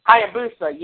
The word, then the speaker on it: Hayabusa: Hayabusa. – Hayabusa, yes.